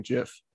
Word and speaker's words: GIF: Like, I just started saying GIF. I don't know. You say GIF. Is GIF important to you? – Yeah, very much so. – Okay. – I'm a big hard G guy.